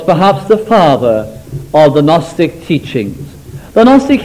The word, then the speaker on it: Gnostic: Now then, there appeared in the early church in those first centuries what is known as the Gnostic heresy. Now, I never know whether to say Gnostic or Gnostic. It's spelled G-N-O-S-T-I-C.